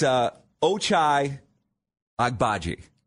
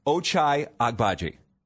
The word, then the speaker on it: Ochai Agbaji: So his name is Ochai Agbaji. – Okay. – I believe I said that right.